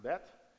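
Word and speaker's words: Bet (בית): It's pronounced Bet.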